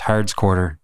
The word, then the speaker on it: Howard's Quarter: This place called Howard's Quarter, if you read it, but it's pronounced Hard's Quarter.